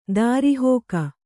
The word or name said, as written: ದುರುಹಿಕೆ